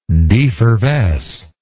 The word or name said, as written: defervesce